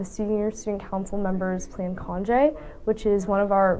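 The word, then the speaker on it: Conge: That's D-A-N-I. Among their activities is the Conje event. That's C-O-N-G-E, pronounced Conje.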